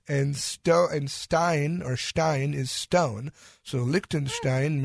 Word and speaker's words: Liechtenstein: Okay, so our next country, and I might butcher this name, but Liechtenstein? – She definitely will. – Yes, Liechtenstein. So Liechtenstein. I was close.